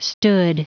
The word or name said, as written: stood